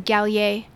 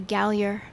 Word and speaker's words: Gallier: And when he got here, he changed it to Gallier. But because we're American, we say Gallier. – Right. – Which might be my favorite fact about him. We're just saying his name wrong all the time.